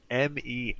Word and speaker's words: Meiwes: Meiwes, like Midas with a V? – It's Meiwes. And I actually didn't know how to pronounce it, so I just YouTubed the video of him saying his own name.